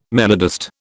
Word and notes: melodist: British English

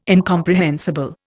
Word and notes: incomprehensible: Indian

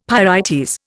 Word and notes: pyrites: Indian

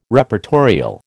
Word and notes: Reportorial: American English

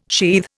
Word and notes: sheathe: Indian

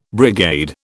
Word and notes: brigade: British English

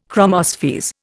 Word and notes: chromospheres: Indian

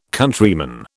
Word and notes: Countryman: British English

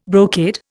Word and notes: brocade: Indian

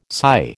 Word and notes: psi: American English

